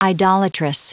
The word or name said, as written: idolatrous